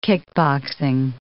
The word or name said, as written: kickboxing